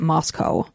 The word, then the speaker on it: Moscow: It's actually spelled just like Russia, M-O-S-C-O-W. – Oh. – But they pronounce it Moscow.